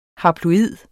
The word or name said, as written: haploid